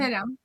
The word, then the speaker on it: Kerem: Bueno, com es pronuncia en alemany? – Sí. – El Kerem en alemany? – Kerem. – Kerem, tal qual.